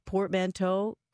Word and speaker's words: portmanteau: Did I pronounce portmanteau correctly? – I believe you did.